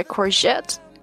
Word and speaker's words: courgette: Okay, courgette. Here, the letter G pronounced like zh.